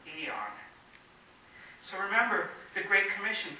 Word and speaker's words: aeon: It's the word aeon, or how we often pronounce it in English, eon.